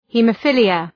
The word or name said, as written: haemophilia